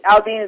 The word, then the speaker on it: Aldean: Jason, is it Aldean? Is how you pronounce his last name? – Mm-hmm.